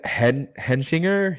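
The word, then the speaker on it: Hechinger: Simon, who's played by Fred Hedgebringer, I think his name is. How do you pronounce it? Let me hold on. Look. Yeah, his name is Fred Henschinger.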